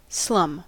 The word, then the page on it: slum: American English